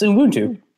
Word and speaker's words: Ubuntu: Yeah, it's Ubuntu.